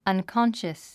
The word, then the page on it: unconscious: ʌnkɑ́nʃəs; アンコォンシャス